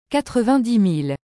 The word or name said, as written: 90000